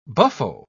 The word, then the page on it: buffo: British English